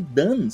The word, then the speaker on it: Dunn: D-U-N-N. Só que Dan se pronuncia da mesma maneira que Don, né? Don que significa feito.